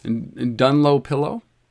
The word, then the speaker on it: Dunlopillow: But here is a mattress by a company, and I think that the way they pronounce it is Dunlopillow.